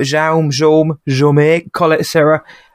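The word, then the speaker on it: Jaume Collet-Serra: It's directed by Jean Colette Serra. I've never known how to say his name, but Jaume Colette Serra.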